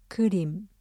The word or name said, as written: 그림